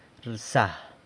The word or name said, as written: rsa